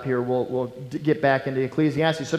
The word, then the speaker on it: Ecclesiastes: Okay, Ecclesiastes.